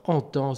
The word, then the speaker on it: Antan: Alors en temps s'écrit A-N-T-A-N, donc comme le temps passé, et un extrait de leur album Les habits de papier, en piste pour un reel, le reel des marguerites.